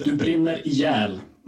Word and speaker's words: Du brinner ihjäl: Yeah, it's called Du brinner ihjäl. – So I was way wrong when I said it my way.